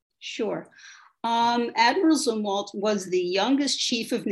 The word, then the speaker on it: Zumwalt: But you also introduce us to a really interesting person who I never knew anything about, Admiral Elmo Zumwalt. Am I saying that right? – Zumwalt.